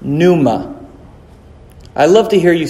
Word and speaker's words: pneuma: Okay, it's one syllable. Pneuma. Maybe two. Pneuma. Two.